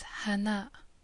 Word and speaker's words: Hana: And the last feminine marker, again pronounced as an A, is an Alif followed directly by a Hamza on the line. And again, the sound is A. So the last letter is Hana, the A sound at the end.